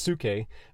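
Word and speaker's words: suke (ψυχή): The word here is actually, well, we would pronounce it psyche, but the Strong's is saying it's pronounced suke, but it's the word psyche.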